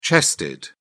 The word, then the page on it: chested: British English